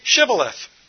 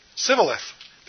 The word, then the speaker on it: Shibboleth: When he said, "No," they said to him, "Then say, Shibboleth." And he would say, "Sibboleth," because he couldn't pronounce it right. They seized him and slaughtered him at the fords at the Jordan. At that time, 42,000 of the Ephraimites fell. Wow. Just couldn't pronounce that shh sound.